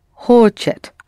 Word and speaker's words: хочет: Only the stress is on о, on хо there. Хо is accented, the first syllable. – Он хочет.